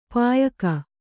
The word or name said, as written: ಪಯ್ಕ